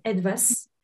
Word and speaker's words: ETWAS: So it's pronounced ETWAS because it is the German name for something,